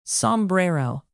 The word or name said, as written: sombrero